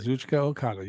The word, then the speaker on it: Kazuchika Okada: Kazuchika Okada.